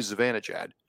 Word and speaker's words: Zibanejad: I'm not sure after watching the international feed game on the NHL Network the other day, and Mika Zibanejad is no longer Mika Zibanejad. The J is not pronounced, and it's the weirdest name I've ever heard.